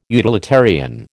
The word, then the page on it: utilitarian: American English